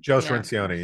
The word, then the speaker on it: Cirincione: Joe Cirincione, yeah.